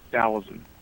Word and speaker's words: Taoism: And then I want to ask you about your philosophy, which is, I think I'm pronouncing it wrong, but you will correct me, Taoism, is that right? – Taoism. – All right, well, we're going to... – T-A-O-I-S-M, yeah.